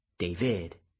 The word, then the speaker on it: David: He tells me his name is David, and that over the years he has grown used to hearing his name pronounced the Spanish way, David, with an accent on the second syllable, like the sound of a musical burst at the end.